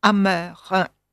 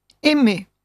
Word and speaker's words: D'un autre amer: Terminons par une chanson fameuse d'Ockeghem, un rondeau, R-O-N-D-E-A-U, un rondeau à trois voix, intitulé « D'un autre amer ». Amer, A-M-E-R, je le prononce exprès mal, car il faudrait dire « d'un autre aimé ».